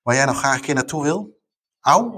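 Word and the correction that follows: Aue: of zo, zeg ik dat goed? Oh, Auwe, maar dat is in, in Oost-Duitsland.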